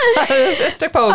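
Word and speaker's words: Istokpoga: I have a hard time pronouncing this name, but I'm telling you, the locals can pronounce it like that. It's Lake Istikpoga. They rattle it off so fast. Istikpoga.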